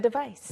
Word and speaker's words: device: 네, 대신에 C일 때는 device, S 발음, 깨끗한 S 발음, 그리고 여기서는 device, Z. With a Z. – 네, Z 발음입니다.